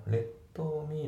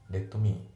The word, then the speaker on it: Let me: で、あと発音なんだけど、 Let me のところの Let が T が抜けますね。 Let me Let me じゃなくて Let me ですね。